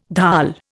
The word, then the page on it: dhal: Indian